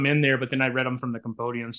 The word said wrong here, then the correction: compendium: So a compendium, I guess is how you pronounce it.